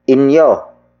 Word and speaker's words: inyo: The next locative pronoun is inyo. Inyo. Inyo. Inyo is spelled as I-N-Y-O.